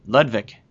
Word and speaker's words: Ludwig: Yeah, the W is pronounced as a V. Ludwig. – V. But you don't know Lou. – Ludwig. – It's not L. Ludwig. – Ludwig.